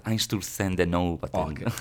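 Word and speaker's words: Einstürzende Neubauten: En Zusarden de Wouter. – vamos a intentar decirlo silenciosamente. – Pero dilo. – Grupo alemán de industrial. Einstürzen de Neubauten.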